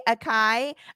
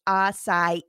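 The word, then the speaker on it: açaí: So acai, I say acai. It says people will often say akai, akai, and even asai, but the proper pronunciation is asai.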